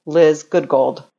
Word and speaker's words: Liz Goodgold: My verbal introductions say, here is a branding expert who is as good as gold, Liz Goodgold. – Brilliant. – So it keeps them in the cadence such that they now pronounce my name and it flows good as gold.